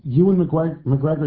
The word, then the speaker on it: Ewan McGregor: I like Ewan McGregor.